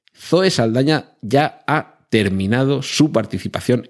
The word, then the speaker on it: Saldaña: Zoe, eh, Zoe Saldaña, Zoe Saldana, eh, se supone que se escribe con ella, pero nunca lo escriben con ella en ningún sitio,